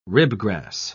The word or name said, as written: ribgrass